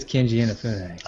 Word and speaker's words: Keiji Inafune: Isn't it Keiji Inafune? I believe it's Keiji Inafune.